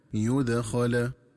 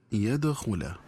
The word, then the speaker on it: يدخل: وكذلك ينبغي التنبه للنطق الصحيح لكلمة يدخل، فهي تنطق بضم الياء وفتح الخاء هكذا يدخل، ومن الخطأ قراءتها بفتح الياء وضم الخاء هكذا يدخل.